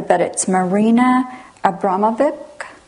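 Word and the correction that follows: Abramović: Yes? – Abramovich. – Marina Abramovich.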